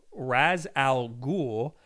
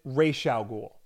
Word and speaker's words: Ra's al Ghul: And they pronounce it Ra's al Ghul. Mm-hmm. And like that's the way most people pronounce it up until the Nolan movies where it was Ra's al Ghul.